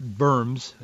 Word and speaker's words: Worms: Today in 1521, Martin Luther went before the Diet of Worms, but it's pronounced Berms, as many of you know.